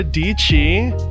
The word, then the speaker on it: Deechee: So we're here with Sarah Deechee, rhymes with peachy, woo, on the podcast.